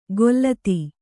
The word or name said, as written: ಗೊಲ್ಲತಿ